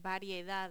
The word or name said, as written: variedad